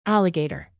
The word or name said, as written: alligator